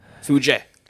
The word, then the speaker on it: Fuji: Mount Fiji. – Fuji. – Andres, what's yours? – Is that how you pronounce it? – Fuji.